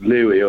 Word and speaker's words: Lewy: Levy or Levi? – It's, well, people call it Lewy, although the original person who described it was called Levy. So it's a controversy, but most people call it Lewy.